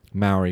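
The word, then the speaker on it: Māori: The song is dedicated to, is he say Maori? Is that how you say it? – Maori, yeah. – Maori, thank you.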